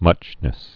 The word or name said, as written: muchness